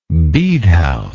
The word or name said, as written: bedehouse